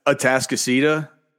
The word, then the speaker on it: Atascocita: I can't pronounce the school, but it was like Anticosta or something. – Oh, Atascocita. – There you go.